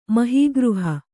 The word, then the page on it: ಮಹೀಗೃಹ: mahī gřha